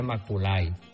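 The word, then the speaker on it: Macaulay: Se escribe Maculay. Herbert Maculay. Se pronunciaría. Vamos a decirle Maculay. Herbert Maculay. Casi como Heberto.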